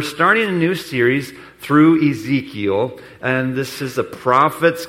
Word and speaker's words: Ezekiel: I want to invite you to your Bibles to Ezekiel. I said that right, Ezekiel.